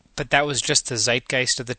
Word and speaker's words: Zeitgeist: Zeitgeist.